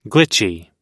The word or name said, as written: glitchy